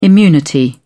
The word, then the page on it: immunity: British English